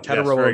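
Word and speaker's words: Tetairoa: So Teteroa. – Teteroa, yes, McMillan, yeah.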